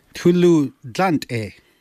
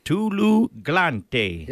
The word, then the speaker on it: Tulu glante: Tulu glante. – Tulu glante. – Yeah, that's perfect.